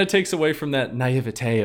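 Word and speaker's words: naïveté: Am I being pretentious by saying naivete.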